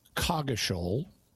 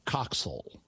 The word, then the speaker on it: Coggeshall: And according to what I was able to find, there are two modern English pronunciations in England for this name. One of them is it's pronounced Coggeshall. – Okay. – The other is it's pronounced Coxel. – Oh, I wouldn't have got either of those. – Yeah. Also, there's an American English pronunciation, which is Coggeshall, different than Coggeshall, the British pronunciation.